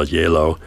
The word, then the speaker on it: Gjeilo: The music we're hearing is by the Norwegian-born composer Ola Yelo, New York-based now, and the last name, by the way, is spelled G-J-E-I-L-O,